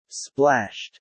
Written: splashed

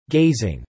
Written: gazing

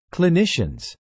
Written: clinicians

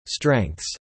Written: strengths

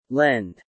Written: lend

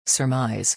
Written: surmise

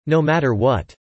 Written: no matter what

